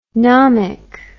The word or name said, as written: nomic